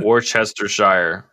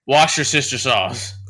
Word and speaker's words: Worcestershire: Worcestershire. Say it right. – Wash your sister sauce.